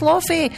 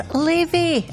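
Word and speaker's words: Laufey: Slavey and Mad Woman, and I have been pronouncing that woman's name wrong for years. I've been saying it's Lofi. Many have gone, put your hand up if you have as well. It's Levy, Icelandic name, lovely name, Levy there, and Mad Woman, taking us up to 9 o'clock, it's No Doubt.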